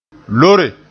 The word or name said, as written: loore